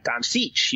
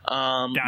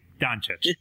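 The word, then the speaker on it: Dončić: Then you've got Doncic. You've got, and I probably pronounced that really wrong and I apologize. – Doncic. – You've got Bagley. – Nice and easy. Doncic.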